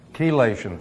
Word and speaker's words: chelation: There were many who are actually, and these were MDs, ironically, that are actually using, is it pronounced chelation or chelation? Chelation? Who? Chelation, thank you.